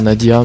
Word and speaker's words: Nadja: Voilà, il faudrait dire Nadia, mais je préfère dire Nadja, car ça s'écrit Nadja avec un J.